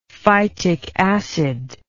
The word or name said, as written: phytic acid